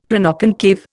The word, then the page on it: Planoconcave: Indian